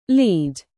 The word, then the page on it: lead: British English; verb